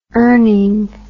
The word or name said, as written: earning